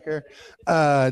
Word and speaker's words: Herrera: Jack Herrera, rhymes with hair, right? Jack Herrera, Dr. Levesque, who I had the opportunity to pay my respects to while he's in hospice care.